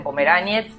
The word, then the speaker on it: Pomeraniec: El apellido lo pronunciamos de la manera que quieran, porque si vamos a Europa del Este me dicen Pomeráñez, ni siquiera Pomeráñez, porque la C se pronuncia como TZ, digamos.